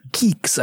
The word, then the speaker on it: Guix: You know, there's also, the GNU project has Geeks, I believe it's pronounced, G-U-I-X,